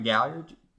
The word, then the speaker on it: McGalliard: My name is Grant McGalliard. You can find me on Twitter at Grant McGalliard. Spelled like it sounds with all those vowels.